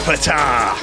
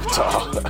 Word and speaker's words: Ptah: The Curse of Ptah. – Ptah. – I think that's how you pronounce it. – I think you don't say the P.